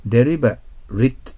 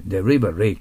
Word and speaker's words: deliberate: deliber RIT と読むときとですね、 deliberate と読むときと違いますよね。 rate と読むときは動詞になるんです。 deliberate と読むときは形容詞になるんです。